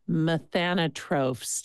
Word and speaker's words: methanotrophs: methanotrophs. Did I say that correctly? – You got it.